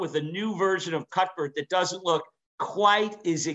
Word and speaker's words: Cuthbert: So if Aldi's comes out with their, they have, you call it Cutbert? – Cutbert. Yeah. Cutbert. Yeah. – So you don't pronounce the TH, th, you pronounce it T. So it's Cutbert.